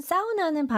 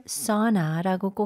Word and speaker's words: sauna: 네, 그리고 여러분 sauna는 발음을 sauna라고 꼭 해주세요. sauna라고 하면 안 돼요, 영어로는.